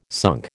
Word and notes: sunk: American English

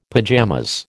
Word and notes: pajamas: American English

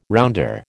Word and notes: rounder: American English

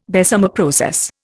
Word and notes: Bessemer process: Indian

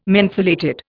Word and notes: mentholated: Indian

